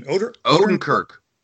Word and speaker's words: Odenkirk: Odenkirk.